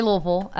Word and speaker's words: Louisville: I say Louisville. In fact, when I first went to Purdue, I started saying Louisville to people because they didn't understand me. And I felt really kind of like an imposter saying Louisville. That's not how I pronounce it.